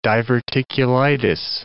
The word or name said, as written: diverticulitis